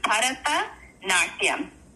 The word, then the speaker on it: Bharatanatyam: Is Bharatanatyam. – Okay. – I hear you say. – No, I'm going to say it. But correctly, Betty will say it together. Bharatanatyam.